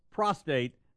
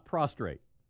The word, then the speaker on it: prostate: Prostate cancer. That extra R makes it all wrong, uh-huh. Okay? It's just all wrong, okay? It's prostate. Not prostrate.